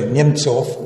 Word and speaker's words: Nemtsov: Era, ecco, anche questo, cercatelo in internet, Nemkov, scritto Nemkov, e pronuncia in russo è Nemtsov, va bene.